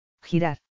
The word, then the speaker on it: Girar: La G es gutural y la R, tajante, Angelique hizo un gesto con la mano como si cortara algo. Girar.